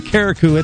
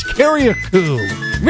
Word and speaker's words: Carriacou: I've been saying Carriacou. It's Carriacou.